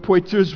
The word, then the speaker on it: Poitiers: One of my favorite authors is Hilary of Poitiers. He was and that's a guy, I got the name right this time. Hilary of Poitiers was a church father in the early 300s.